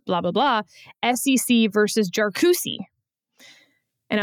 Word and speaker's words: Jarkesy: And lo and behold, man, the oral advocate for Jarkusi stood up and corrected the chief. – Wow. – Because he knows that podcasts like ours, you know, really rely on that initial pronunciation. So we will keep pronouncing it SEC v. Jarkusi based on counsel, and we will then ignore the Chief Justice.